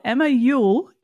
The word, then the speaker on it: Emma Youell: This week I've got the one for Emma Youell.